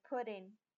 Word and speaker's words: putting: 我们先看 putting 在做连音的时候呢，快的时候念快的时候，它 putting 的 tt 的音呢会被省略，变成小 d 的音，变成 putting。